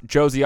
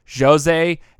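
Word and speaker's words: Jozy: I mispronounce Josie Altidore's name by saying Jose and Jose.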